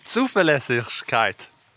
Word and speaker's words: Zuverlässigkeit: Zuverlässigkeit. – En gång till. – Zuverlässigkeit. – Zuverlässigkeit.